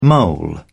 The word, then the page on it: mole: British English